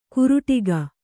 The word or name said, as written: ಕುರುಟಿಗ